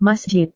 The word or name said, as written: masjid